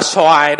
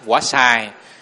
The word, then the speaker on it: xoài: Tại vì cái quả xoài đó, âm chữ Hán nó là xài. Mà người Việt Nam đọc là xoài. Nhưng mà người miền Nam thì sống gần người Hoa rất là nhiều, cho nên vẫn gọi là quả xài.